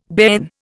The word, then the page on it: bairn: Indian